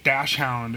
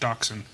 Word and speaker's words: Dachshund: A lot of people say Dachshund, and I don't... It's like acceptable, but it's wrong. So many people say it that it's just... become forced into being acceptable, but it's pronounced Dachshund. And if you want to throw a German inflection on it, you can. I don't. I just say Dachshund.